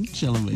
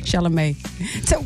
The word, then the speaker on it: Chalamet: Chalamet, yeah. – Chalamet.